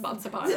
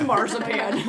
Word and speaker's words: Marzipan: My dad insisted that it's pronounced Mazapan, and I was like, it's not. It's Marzipan.